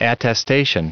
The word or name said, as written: attestation